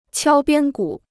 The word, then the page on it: 敲边鼓: qiāo biān gǔ